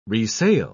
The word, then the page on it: resail: British English